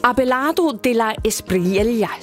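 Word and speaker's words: Abelardo de la Espriella: Abelardo de la Esprilla.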